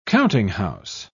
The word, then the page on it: countinghouse: British English